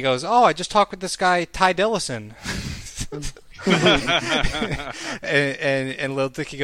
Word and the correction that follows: Ty Dolla $ign: goes, you mean Ty Dolla $ign?